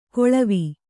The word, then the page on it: ಕೊಳವಿ: koḷavi